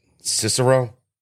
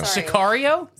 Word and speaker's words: Sicario: No, Cicero. – Yeah. Sounds familiar. – Cicero. Oh, yeah. Cicero. – Thank you. There we go. – Sicario.